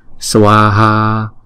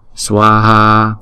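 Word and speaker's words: svāhā: Chữ A có dấu gạch ngang trên đầu, chúng ta phải đọc cái dài hai nhịp, cho nên chữ swaha này chúng ta đọc là hai âm và mỗi âm hai nhịp.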